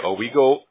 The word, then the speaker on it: Owego: I have 373 West Creek Road, and this is in Owego, if I'm saying that right, is it right? – Yep. – Okay, yep. – Owego, New York, and that would be 13827, is that right? – Yep.